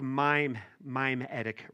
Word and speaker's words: mimetic: Like mime-etic rivalry. That's how it's spelled, okay?